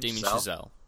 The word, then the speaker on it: Chazelle: How do you say his last name? – Damien Chazelle.